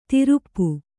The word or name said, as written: ತಿರುಪ್ಪು